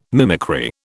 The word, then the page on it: mimicry: British English